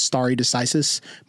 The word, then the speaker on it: stare decisis: I believe the principle is stare decisis. Am I pronouncing that correctly, Damon? – Yes.